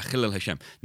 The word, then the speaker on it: Chilul Hashem: We pronounce C-H, ch, so Jackie Chan. Oh, I love Jackie Chan films. So yeah, a Chilul Hashem.